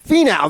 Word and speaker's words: Finau: Finau, there it is.